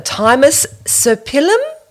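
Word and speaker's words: Thymus serpyllum: And a thymus serpillum? Mm-hmm. Is that right? – Yes.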